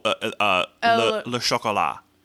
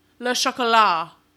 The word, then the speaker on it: Le Chocolat: Karuna Stamel as La Petite Princesse, and Diobia Opari as, oh, it's, this is pronounced differently because there's no E at the end. – Oh, Le Chocolat. – Le Chocolat.